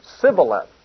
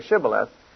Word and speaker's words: Shibboleth: And he said Sibboleth, because he couldn't say Shibboleth, for he could not frame to pronounce it right.